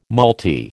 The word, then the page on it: malty: American English